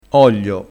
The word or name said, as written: oglio